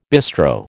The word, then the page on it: bistro: American English